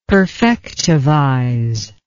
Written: perfectivize

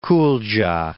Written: Kuldja

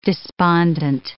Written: despondent

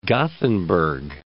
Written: Gothenburg

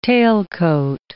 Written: tailcoat

